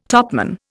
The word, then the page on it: topman: Indian